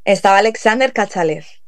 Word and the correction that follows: Kachalaev: Sí. Bueno, Kachalaev, perdona, vale.